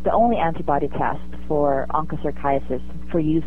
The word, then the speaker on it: Onchocerciasis: and I'm probably not pronouncing it correctly, Onchocerciasis, also known as river blindness. Is that right? – Yeah, that's right. It is a tough one to pronounce, Onchocerciasis.